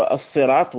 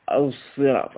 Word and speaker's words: الصراط: ويطلق كذلك الإشمام كما رأينا في المجلس السابق على النطق بين حرف الصاد ليس بصاد خالصة ولا بزاي خالصة ولكن بينهما اهدنا هكذا كأنه ينطق ليس صاد لا يقول الصراط ولا يقول الزراط وإنما يقول الصراط هكذا كأنه يعني يشم الصاد صوت الزاي